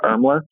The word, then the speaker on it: Irmler: It's just, it's Joshua Irmler. And then on Twitter, it's Joshua Irmler as well. The last name is spelled I-R-M-L-E-R.